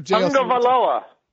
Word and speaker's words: Tagovailoa: No, but really, if you go back, I found a video of him pronouncing it when he got to Alabama, like on campus, like for the media guide or whatever. I guess they record it. And he says Tagovailoa.